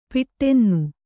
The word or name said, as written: ಪಿಟ್ಟೆನ್ನು